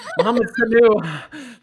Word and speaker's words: Mohamed Sanu: I mean, they got the Mohamed Sanu. I said it wrong. Mohamed Sanu thing done like right away.